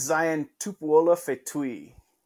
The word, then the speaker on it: Zion Tupuola-Fetui: Yeah, it is Zion Tupuola-Fetui.